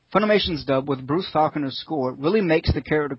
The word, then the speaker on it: Falconer: With Bruce Falconer's, is that correct? Falconer's? – Well, I suppose it's wrong, but that's correct pronunciation.